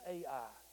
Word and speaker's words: Ai: There is Ai, or I, however you want to pronounce it is right. I'll keep on pronouncing it as Ai.